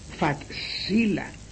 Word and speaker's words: facila: Se escribe facila y se lee fatsila. No es excepción, es que la C siempre es una TS.